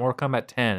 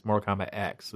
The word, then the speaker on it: Mortal Kombat X: I mean, I started playing Mortal Kombat X, and apparently that's actually how it's pronounced. It's not Mortal Kombat 10. It's Mortal Kombat X.